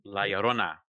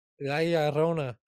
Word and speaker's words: La Llorona: La Llorona. – La Llorona.